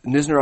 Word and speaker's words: Knizner: Silent K. – Okay. – Who Nisner I've seen here in Fall League,